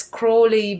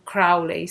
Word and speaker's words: Crowley: The term magic spelled with a K was popularized by the very famous esotericist Aleister Crowley, sometimes also pronounced as Crowley by American people, but I will stick to the British pronunciation Crowley since he was British.